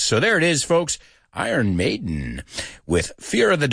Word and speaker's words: Iron: There it is, Fear of the Dark by Iron Maiden, que ya sabemos que no se pronuncia Iron, sino Iron.